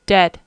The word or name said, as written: dead